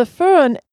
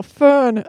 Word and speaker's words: Föhn: I had a friend from Switzerland try to teach me how to say Föhn, and I still probably don't say it correctly, do I? – Oh, the first try was perfect. – Oh, wow, Föhn.